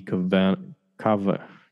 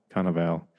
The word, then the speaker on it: Cannavale: Bruce Dern, Bokeem Woodbine, Bobby Cannavale. Cannavale.